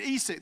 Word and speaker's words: Esek: He names the well Esek. I love that. Actually, I looked that up. That is actually the correct pronunciation. So there you go.